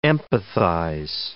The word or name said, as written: empathize